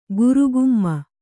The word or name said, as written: ಗುರುಗುಮ್ಮ